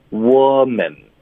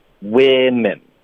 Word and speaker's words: women: 可是它的发音是不一样的，所以针对单数的，它的发音是 wo。是一个的音 woman，然后复数的它的发音是 we 的音 women， OK，所以它两个词的第一个音节写的一样，可是它发音是不一样的，第二个音节写的是不一样，可是它的发音是一样的，所以单数复数的都发 men，所以你听对了 OK，没错。